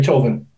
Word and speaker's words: Chauvin: It's been over 300 days, almost a year, since George was killed by an evil coward, Derek Chauvin. That's how you say his name, I think. – Yeah.